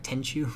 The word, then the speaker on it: Tenchu: So earlier I mentioned the Tenchu thing, which there is some news related to this, but just to warn any Tenchu fans. Am I saying that right? Tenchu? – Yeah, Tenchu.